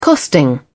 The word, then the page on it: costing: British English